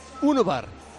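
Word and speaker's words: Ünüvar: ¿Cómo se pronuncia? – Unubar. – Urbar. – Vale. Unubar. – Unubar, como si fuera de... – Porque es de origen turco, es neerlandés de origen turco.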